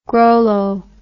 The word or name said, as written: grollo